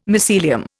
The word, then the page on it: mycelium: Indian